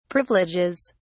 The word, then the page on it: privileges: British English